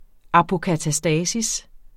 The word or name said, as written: apokatastasis